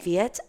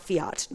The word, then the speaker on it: Fiet: Fiat, F-I-E-T. Not Fiat, not like the car. Oh. Although it is probably about the size of a Fiat. The F-I-E-T, I don't know.